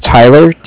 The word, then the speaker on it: Tyler: My name is Tyler, T-Y-L-E-R. And I'm calling with Cardiff. It's spelled C-A-R-D-I-F-F.